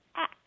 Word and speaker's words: ask: I had begun to notice, oh, I don't know when, but years ago, that there was an alternative pronunciation, and it was ax, like it was spelled A K S.